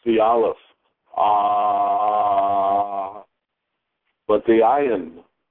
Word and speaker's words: ayin: And we are taught in America that the ayin has no sound, just like the aleph. And just like the hey, when it's at the end of the word, is not pronounced. The ayin has no sound. But again, it's not quite true. It's a difficult sound to make, because it's at the back of the throat.